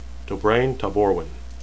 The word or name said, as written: dobraine